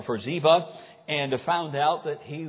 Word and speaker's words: Ziba: But David sought to show kindness to Saul and to Jonathan, and found out that there was a servant named Ziba, however you want to pronounce it. I pronounce it Ziba.